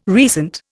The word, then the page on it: recent: Indian